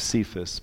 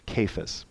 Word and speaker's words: Cephas: We pronounce it many times Cephas, but it's Cephas. Now Cephas, or Peter, came to Antioch, Paul says, and I opposed him to his face because he stood condemned.